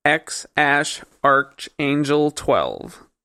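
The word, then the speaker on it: X Æ A-12: And Elon said, it is X-A-12 or X-Archangel 12. That is how you pronounce that name.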